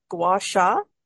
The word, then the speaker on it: gua sha: Correct me with the pronunciation, gua sha and yes, correct. Yeah.